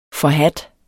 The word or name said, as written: FORHADTE